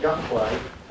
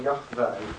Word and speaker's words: Yahweh: Because the name ceased to be used, because people stopped using the name, we're not absolutely sure about the pronunciation, but Yahweh or Yahweh is pretty certain.